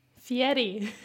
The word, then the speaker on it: Fieri: You got Fieri right. – They get it wrong every time. You knocked it out of the park. – I'll pat myself on the back for that. – Fieri.